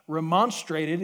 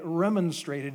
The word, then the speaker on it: remonstrated: There's a British pronunciation and an American pronunciation. Remonstrated is the American, and remonstrated is the British one.